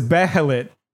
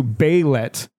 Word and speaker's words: Behelit: So the word is Behelit, which is one of the things in Berserk, and they're correcting me to Behelit, and that's just not right.